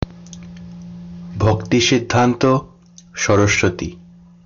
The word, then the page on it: ভক্তিসিদ্ধান্ত সরস্বতী: Bengali; [bʱɔktisid̪d̪ʱanto ʃɔrɔʃbɔti]